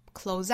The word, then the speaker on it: close: 这个 close 发音尾部是 z 浊辅音那最后的 up 口型不要过大不是 r close